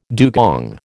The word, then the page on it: dugong: American English